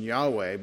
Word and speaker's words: Yahweh: The Tetragrammaton is written Yod-Heh-Vav-Heh, and so we conjecture the pronunciation Yahweh, but it's the sacred name of God built on the root for self-existence, for the word Hayah, to be or to exist.